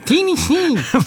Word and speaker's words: Tennessee: No, me lo dicono così gli americani. Tennessee, anche col tono di voce cambiare.